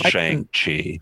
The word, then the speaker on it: Shang-Chi: Sorry, it's pronounced Shang-Chi. – It's Shang-Chi. – It's actually Shang-Chi. – Is it Shang-Chi? Because they actually pronounce it in the movie. And it's Shang-Chi.